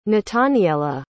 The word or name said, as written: nataniella